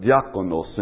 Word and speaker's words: diakonos: diakonos in Greek. And it's almost exactly the same way it's pronounced in Spanish.